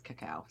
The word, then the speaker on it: cacao: I think it's cacao. So sorry about that. Little morning differential on how to pronounce things.